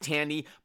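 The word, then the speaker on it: Thandie: And the thing about Tandy Newton, I know it's pronounced Tandy, but it's spelled Fandy to me.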